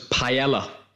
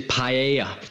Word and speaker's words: paella: And also the other pronunciation was paella, which is paella.